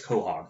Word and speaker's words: Quahog: It's spelled weird, but we out there, it's Quahog.